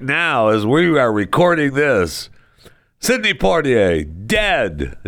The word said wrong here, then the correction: Poitier: Is it Portier? Porter? Sidney Portier? I want to make sure I pronounce it right. I don't want to. I know this. It's not Portier. And it's not Porter. It's Sidney. Poitier? Okay.